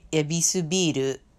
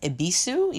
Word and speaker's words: Yebisu: Here you would say ebisubiru. It's pronounced ebisu, even though there's a Y in front of it. And they don't say beer here, they say biru.